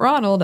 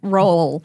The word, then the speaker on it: Roald: No. Roald. it's a Norwegian word or a Norwegian name. It's both, I suppose. I'm pretty sure there's an extra, like, syllable worth of vowel sound that I'm not pronouncing correctly at the front of it, but...